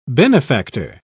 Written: benefactor

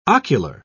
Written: ocular

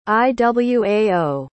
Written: Iwao

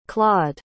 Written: claud

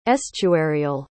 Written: estuarial

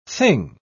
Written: thing

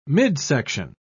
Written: midsection

